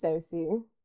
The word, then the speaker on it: Sosie: It's spelled like Josie, but it's Sosie.